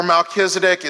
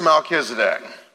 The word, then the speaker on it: Melchizedek: We've got Genesis chapter 14, where Melchizedek is mentioned in his time with Abraham and what went on there.